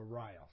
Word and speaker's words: Ryle: Yeah, John Charles Riley, or Ryle, excuse me. If you want, I pronounce the E. No.